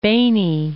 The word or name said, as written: Beni